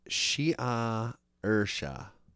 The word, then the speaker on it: Saoirse: Before kissing the name with his lips and pronouncing it, Shea Ursha. – Yeah. Good luck. The letters don't match up to the sounds in Irish.